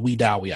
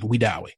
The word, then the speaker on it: Wedowee: Well, you know, I'm from a small town in Alabama called Weedowee, Alabama. And it's spelled We Do Weed minus the E, minus the D on the end, Weedowee, right?